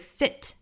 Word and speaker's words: fit: Sail on the boat, fit for Bond villain，后面这个 fit 一定要注意，不要读成了 feet，嘴巴稍微松那么一点就会变成脚的那个复数了，要把它读成一个 a 的松口的 fit，尾部的这个 t 呢再省掉 fit for，因为这个后面的 for 要做弱读嘛，要读成 a fit for Bond villain， Bond 尾部的省掉 Bond villain。